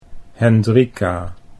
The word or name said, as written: Hendrika